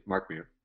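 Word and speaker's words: Mark Meer: And yes, you pronounced my name correctly. Mark Mir.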